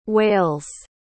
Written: whales

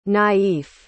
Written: naïf

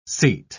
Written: seat

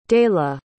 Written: dayla

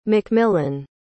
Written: mcmillan

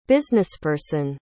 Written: businessperson

